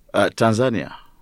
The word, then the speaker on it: Tanzania: So you're from East Africa, you speak Swahili, you're not from the country south of Kenya, but how do you pronounce it? Is it Tanzania or Tanzania? – Tanzania.